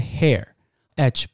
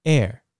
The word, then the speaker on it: heir: 这个继承人的英文是 heir，heir，它第一个字算是 h，可这个 h 不发音哦，所以不要念成头发那个 hair，h 不发音，念 heir。